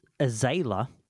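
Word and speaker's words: Azayla: Azalea, sorry.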